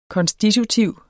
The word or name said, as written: konstitutiv